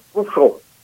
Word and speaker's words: Khrushchev: Mà đúng tiếng Liên Xô nó đọc là Khrushchev.